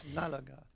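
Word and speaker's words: Nalaga'at: I hope I'm saying it right. Did I say it right? – Yeah, Nalagat.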